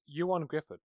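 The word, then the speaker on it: Ioan Gruffudd: Apparently it's Ewan Griffith. Ewan Griffith. – Ah, Ewan. Doesn't look like that how it's spelled.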